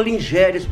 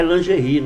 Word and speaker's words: lingerie: É lingerie, né? Na verdade se escreve Lingéries.